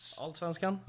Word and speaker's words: Allsvenskan: Allsvenskan? – Yes.